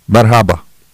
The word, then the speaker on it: merhaba: It's spelled M-E-R-H-A-B-A. So, merhaba. Merhaba. If it's Turkish, it probably sounds like this.